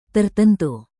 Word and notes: tertentu: Indonesian

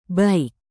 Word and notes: baik: indonesian

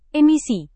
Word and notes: emisi: indonesian

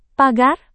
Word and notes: pagar: Indonesian

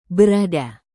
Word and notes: berada: indonesian

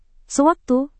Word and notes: sewaktu: indonesian

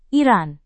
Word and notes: iran: indonesian

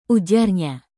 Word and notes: ujarnya: indonesian

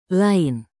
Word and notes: lain: Indonesian

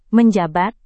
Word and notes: menjabat: Indonesian

